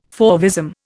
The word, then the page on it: fauvism: Indian